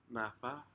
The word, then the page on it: nada: Aneityum